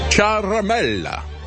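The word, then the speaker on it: Ciaramella: I found out how to pronounce it. Charamella.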